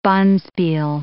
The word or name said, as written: bonspiel